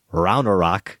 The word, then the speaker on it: Ragnarok: The most recent entry in the MCU is Thor, Ragnarok, correct pronunciation learned from y'all's podcast.